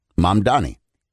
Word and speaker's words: Mamdani: Zoran Mandani. Mandani? Yeah, Mandani, right? Mamdani. – This is the new mayor of New York City. Here he is. – Yeah. – Zohran Kwame Mamdani.